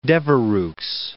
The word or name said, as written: Devereux